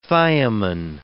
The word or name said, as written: thiamine